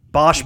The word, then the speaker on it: Bach: How do they pronounce it? It was like Bosch. – Bosch, I think. – Bosch. That's Bosch.